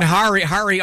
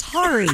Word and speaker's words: Hari: I've been saying Hari all morning. I just said Harry. – Said Harry. Damn it. Yeah. – It happens. – Hari, look, I don't know if you, I wrote my pronouncer out. Damn it. – Amazing. Amazing. – He wrote Harry. – He wrote out Harry. It's Hari.